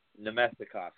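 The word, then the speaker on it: Namestnikov: And while these kind of guys like Pajot and Hainsey and DeMilo I'm going to butcher his name again, the guy on Tampa, Nemstikov? – Nemestikov, yeah. – Thank you, sir.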